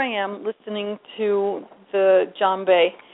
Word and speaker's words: djembe: It's spelled with a DJ, but most people say, the correct pronunciation is djembe. Most people say djembe, and that's incorrect.